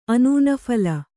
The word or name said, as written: ಅನೂನಫಲ